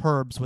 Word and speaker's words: herbs: And it says the British pronunciation is herbs with an H, while the American pronunciation is herbs without the H.